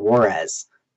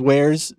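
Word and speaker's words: warez: Some pronounce it Juarez. I don't think it was Juarez, though. – I think now it has been settled on as wares scene. – Yeah, soft wares. It makes more sense. – But we 100% called it Juarez, for sure. – Thank you. You know, yeah, it's 100% wares, but...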